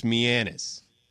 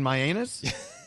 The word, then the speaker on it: Mianus: There's this place called, it's spelled M-I-A-N-U-S, and I think it's Mianus, but people call it Mianus.